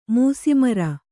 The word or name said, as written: ಮೂಸಿಮರ